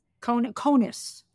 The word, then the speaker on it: Kounis: We had a woman write in, and she is struggling with something called, I don't know if I'm going to pronounce this correctly, Kunis syndrome? Uh-huh. K-O-U-N-I-S? – Conus, I think is pronounced, yep.